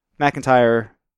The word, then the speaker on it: McIntyre: For us, McIntyre is more like four syllables because the Y sound, McIntyre, is a diphthong.